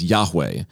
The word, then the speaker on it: Yahweh: And in Hebrew, you would pronounce it as Yahweh.